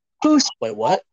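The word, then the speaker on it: Fus Ro Dah: Fusroda. That's how you would pronounce it.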